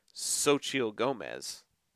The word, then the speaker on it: Xochitl: In fact, not just in the Hispanic side of things with Xochitl Gomez. That's how you pronounce it.